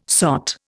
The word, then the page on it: sot: Indian